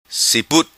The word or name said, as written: Siput